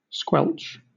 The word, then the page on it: squelch: British English